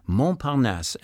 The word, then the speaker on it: Montparnasse: Montparnasse.